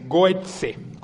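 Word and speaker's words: Gøtzsche: Hay un investigador danés muy prestigioso que se llama Peter Goetze, se escribe con diéresis la O, bueno, por cómo es el danés, Goetze, con Z, Goetze. Y hay un investigador catalán que afirma también,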